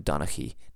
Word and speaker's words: Donnchaidh: The McFarlands, the McGregors, but also Clan Donnchaidh did. Now, I'm pronouncing it like this. There's a couple different ways to spell this that I've seen. I think the proper way is Donnchaidh.